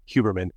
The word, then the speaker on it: Huberman: I learned how to pronounce that correctly, Huberman, after watching his podcast, because he has a podcast as well.